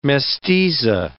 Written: mestiza

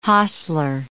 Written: hostler

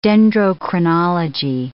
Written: dendrochronology

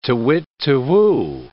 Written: tu-whit tu-whoo

